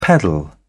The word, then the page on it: peddle: British English